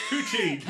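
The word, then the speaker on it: poitín: Is it actually pronounced like that? P-O-I-T-I-N, poutine. – Yeah, but is it pronounced that way? – Let's ask Wikipedia, which is probably not going to work because I'm wearing headphones. Poutine. – That's it, yeah.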